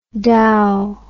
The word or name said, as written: dao